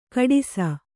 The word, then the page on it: ಖೋಡಾಸು: Kannada